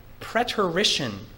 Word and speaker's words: preterition: So secondly, I want you to consider with me a doctrine which you have heard from me before called preterition. Preterition, I'll spell that for you, it's not a common word. P-R-E, preterition, P-R-E-T-E-R-I-T-I-O-N.